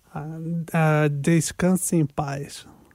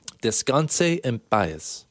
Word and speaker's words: Descanse en paz: How do we say this? – Descanse en paz. – Descanse en paz. – Yep. – Descanse en paz.